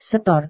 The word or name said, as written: setor